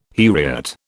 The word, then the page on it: heriot: British English